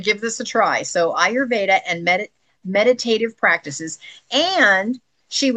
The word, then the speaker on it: Ayurveda: Now, I'm going to try to say this word correct. Through mindful movement, Ayurveda. Did I say that right? I did.